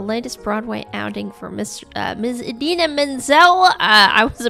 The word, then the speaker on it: Idina Menzel: Anyway, so Idina Menzel, as her name is appropriately pronounced, she is, she's most famous for singing two really big, loud, famous songs.